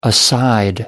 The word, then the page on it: aside: American English